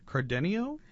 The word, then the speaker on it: Cardenio: The history of, let me make sure I have it pronounced correctly, Cardenio? – Yes, the history of Cardenio.